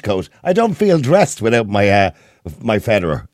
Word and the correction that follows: fedora: Is it Federer? Is that how you pronounce it? – Fedora.